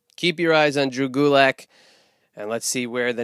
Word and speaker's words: Gulak: Uh, and then, you know, follow me on Twitter at Drew Gulak, D R E W G U L A K. It rhymes with attack.